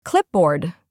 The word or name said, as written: clipboard